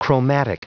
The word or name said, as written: chromatic